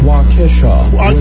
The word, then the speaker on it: Waukesha: Waukesha, Wisconsin.